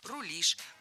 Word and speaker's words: рулишь: Кроме того, ударение в нашем слове тоже стоит всегда за корнем, как и в начальной форме. «Рулить», «рулит», «рулим», «рулишь».